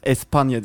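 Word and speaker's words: España: N'nin üzerine gelirse N ve Y diye okunur. Mesela Espana diye yazılır. Espanya diye okunur.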